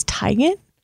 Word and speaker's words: Teigen: Her name's Teigen? – According to her social media, it's Teigen. – No. Yeah. – We've been saying it wrong forever. – She checked with her mom and confirmed it.